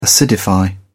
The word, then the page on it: acidify: British English